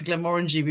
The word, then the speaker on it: Glenmorangie: We were always taught when I used to work for Glenmorangie, we were always taught rhymes with orangey, which of course isn't really a real word, but yeah.